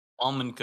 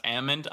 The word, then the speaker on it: almond: It's a Chinese almond cookie. Very good. Except it's actually pronounced almond. I just say almond so that I don't get killed by the public.